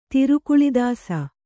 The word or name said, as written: ತಿರುಕುಳಿದಾಸ